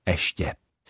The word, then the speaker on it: ještě: Tak třeba slovo ještě vyslovujeme velmi pravidelně bez počátečního j, totiž jako ještě.